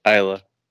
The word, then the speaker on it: Isla: I've been saying her name wrong. Like, I've been saying Isla Dawn. Like, it's Isla. – And I heard the Isla.